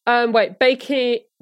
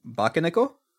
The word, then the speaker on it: Bakeneko: Saying that he loves the Cheshire Cat, but it was based on a Japanese folklore of a shape-shifting cat called Bakeno. Bakeneko, I think is the pronunciation. – Bakeneko? – Yeah, that sounds better.